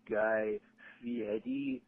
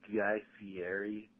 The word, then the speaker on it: Guy Fieri: Actually, it's Guy Fieri, not Guy Fieri. You mispronounced his name.